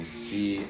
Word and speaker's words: Sea: Just call me C _, all one word, but C is spelled like the ocean, S-E-A.